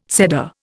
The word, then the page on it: Seder: Indian